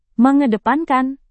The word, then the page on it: mengedepankan: Indonesian